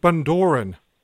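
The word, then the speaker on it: Bundoran: Is it Bonduran? – Yes. – Yes. – I pronounced it wrong.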